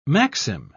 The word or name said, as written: maxim